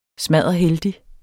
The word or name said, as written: smadderheldig